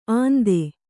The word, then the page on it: ಅಂತೆ: Kannada